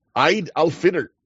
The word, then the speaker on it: Eid al-Fitr: Eid al-Fitr. – I don't know how to say it. Yeah. – yep. Eid al-Fitr.